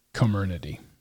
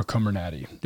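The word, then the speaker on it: Comirnaty: you're being offered comernity or comernati, depending on how you say it.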